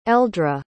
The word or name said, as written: aeldra